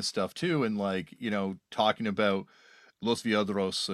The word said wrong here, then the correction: Violadores: and probably worst pronunciation ever there, but like talking about these bands. – Yeah, Violadores from Argentina. – Yeah, Violadores, sorry.